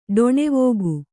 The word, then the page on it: ಠಾಣವಿಕ್ಕು: Kannada